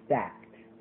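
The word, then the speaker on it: stacte: S-T-A-C-T-E. S-T-A-C-T-E. We find this to be pronounced stacked,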